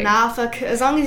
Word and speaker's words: Norfolk: As long as you don't say folk. – Okay. – Like, you can say Norfolk.